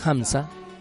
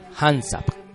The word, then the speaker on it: Hamsa: Por ello debemos tener cuidado al pronunciarlo, porque si pronunciamos este mantra con las terminaciones de consonantes como la P, la T o la C, el resultado no es el mismo. Es decir, Hansap con P, Hansap con P, Hansat con T, Hamzat con T, o Hansak con C, Hamzak con C.